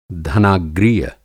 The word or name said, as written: धनाग्रीय